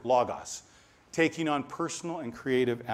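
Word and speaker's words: logos (λόγος): We would probably pronounce it logos, L-O-G-O-S, logos, like the logo on your shirt or the logo on your car. But the Greeks wouldn't pronounce it that way. They'd say logos.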